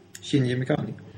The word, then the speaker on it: Shinji Mikami: Shini Mikami. Shinji Mikami.